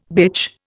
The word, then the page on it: bitch: Indian